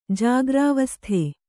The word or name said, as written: ಜಾಗ್ರಾವಸ್ಥೆ